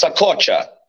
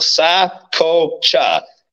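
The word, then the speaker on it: Saccoccia: It's Sacocha. It means pocket in Italian. So I break it down into three syllables for people. Sacocha. And then when they say it like that, it makes it so much easier.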